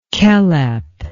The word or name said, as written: Kelep